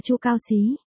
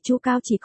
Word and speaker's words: Chu Cao Xí: Khuyển tử là cách nói khiêm tốn, chỉ con cái của mình, tên cũng phải đọc là Chu Cao Xí. Mạnh mẽ chứ không phải Chu Cao chỉ có.